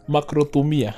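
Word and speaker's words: makrothumia (μακροθυμία): It's makrothumia. – No comment. – I'm serious. MAKROTHUMIA. Makrothumia. Right? But I'm saying it makrothumia. For it to sound like closer to, you know, makrothumia.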